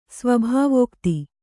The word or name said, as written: ಸ್ವಾಭಾವಿಕತೆ